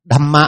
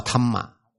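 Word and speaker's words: Dhamma: Đâm Mạ họ đọc là Thâm Mạ.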